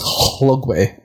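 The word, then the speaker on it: Llugwy: Again, I'm never sure because W-Y is a common ending in Wales, and I think it's just W-A-Y is how it's pronounced. So I'll call it Lugway. LL is a common way to start a Welsh word, and it's actually usually pronounced like HL, so it's Hlugway.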